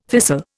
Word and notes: thistle: Indian

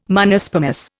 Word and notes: Monospermous: Indian

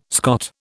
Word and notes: Scot: British English

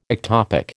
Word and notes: ectopic: American English

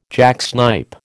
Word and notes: jacksnipe: American English

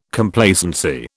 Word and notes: Complacency: British English

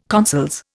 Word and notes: consols: Indian

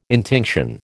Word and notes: intinction: American English

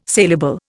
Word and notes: salable: Indian

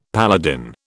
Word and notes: paladin: British English

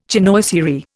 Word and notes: chinoiserie: Indian

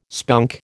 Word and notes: spunk: American English

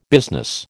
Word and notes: business: American English

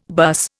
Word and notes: bus: Indian

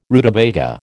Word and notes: rutabaga: American English

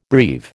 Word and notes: breve: American English

